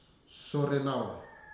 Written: Sorenau

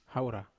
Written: haura

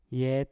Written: yet

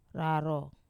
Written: raro

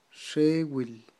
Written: xewil

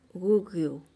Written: gugiyu

